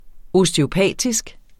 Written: osteopatisk

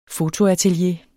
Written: fotoatelier

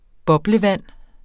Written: boblevand

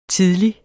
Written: tidlig

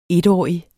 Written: etårig

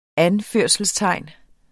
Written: anførelsestegn

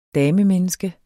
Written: damemenneske

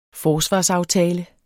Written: forsvarsaftale

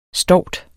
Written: stovt

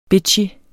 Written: bitchy

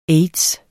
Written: aids

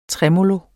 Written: tremolo

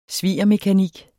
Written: svigermekanik

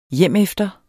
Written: hjemefter

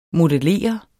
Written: modellere

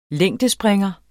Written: længdespringer

